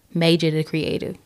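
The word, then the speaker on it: Maejor Da Kreative: Major the Creative. Y'all, give it up for Major the Creative one more time.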